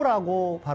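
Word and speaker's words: tango: 땅고라고 발음합니다. 이 땅고라고 하는 게 더 이 스페인적이고 이 부에노스아이레스적인 것 같습니다.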